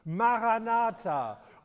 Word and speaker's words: Maranatha: Maranatha, unser Herr kommt.